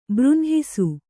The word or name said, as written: ಬೃಂಹಿಸು